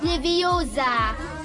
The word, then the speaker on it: Leviosa: Und übrigens hast du die falsche Betonung. Es heißt Leviosa und nicht Leviosa.